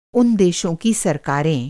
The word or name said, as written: सरकार